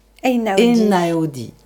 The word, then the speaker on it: Einaudi: Einaudi.